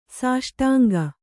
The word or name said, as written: ಸಾಷ್ಟಾಂಗ